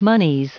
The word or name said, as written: monies